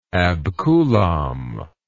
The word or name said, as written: abcoulomb